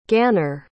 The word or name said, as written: Gaenor